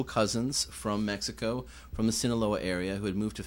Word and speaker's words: Sinaloa: So we reached out to a lot of different folks, and then we came across a band called Los Cuates de la Sinaloa. Jeez, I'm mispronouncing your name. – Sinaloa, yeah.